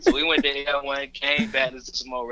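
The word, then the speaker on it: Erewhon: Is that how you say it, Air One? – Yeah, Air One.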